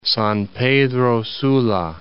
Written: San Pedro Sula

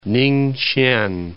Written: Ninghsien

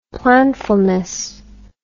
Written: planfulness

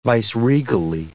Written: viceregally

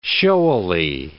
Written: showily

